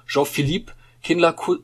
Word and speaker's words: Jean-Philippe Kindler: So schreibt es der Comedian und Satiriker Jean-Philippe Kindler vor kurzem in einem... Habe ich es falsch ausgesprochen? – Nein, alles gut. Alles super.